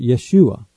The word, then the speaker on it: Yeshua: After the Babylonian captivity, the Hebrew spelling changed, and the name Joshua, as found in the books of Ezra and Nehemiah, would be pronounced Yeshua.